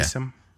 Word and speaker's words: Neeson: Neeson.